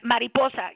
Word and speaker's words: mariposa: Now, we need to talk about is it pronounced mariposa? Did I get that right? – Mariposa. Yeah, mariposa is butterfly in Spanish.